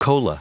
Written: cola

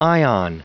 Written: ion

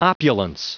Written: opulence

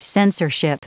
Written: censorship

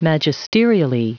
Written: magisterially

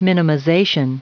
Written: minimization

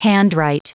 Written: handwrite

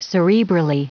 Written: cerebrally

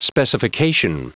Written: specification

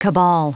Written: cabal

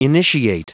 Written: initiate

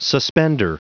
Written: suspender

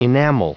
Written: enamel

